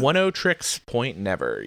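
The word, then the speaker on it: Oneohtrix Point Never: One O' Trix Point Never, yeah.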